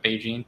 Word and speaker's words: BeiGene: Uh, no, I think it's uh, BeiGene. Is that Manisha? Is that how you say it? Like Beijing, but BeiGene. Okay.